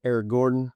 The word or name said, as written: Eric Gordon